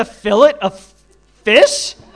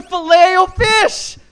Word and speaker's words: Filet-O-Fish: You call it the fillet of fish? Really? No wonder they always look at me like I'm on crack cocaine when I fillet. It's the Filet-O-Fish.